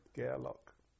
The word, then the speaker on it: Gareloch: You spell that G-A-E-R-L-O-C-H? – G-A-R-E-L-O-C-H, yeah, Gearlock. Which then was a reserve fleet station, the Gearlock. There was all the old wartime battleships and aircraft carriers. All the big ships were in the Gearlock.